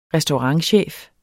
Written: restaurantchef

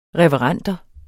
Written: reverenter

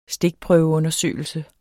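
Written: stikprøveundersøgelse

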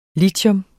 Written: LITIUM

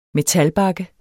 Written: metalbakke

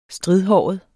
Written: stridhåret

